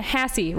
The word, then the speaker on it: Hassey: It's McKenna Hassey, rhymes with sassy.